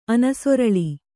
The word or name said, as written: ಅನಸೊರಳಿ